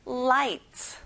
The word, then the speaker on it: light: 가운데 G 발음은 안 해요. light.